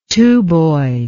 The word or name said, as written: tuboid